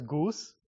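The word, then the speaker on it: GOENV: This is pronounced as Goose. I'm actually not kidding. So Goose and Go Arc.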